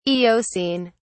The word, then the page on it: eocene: British English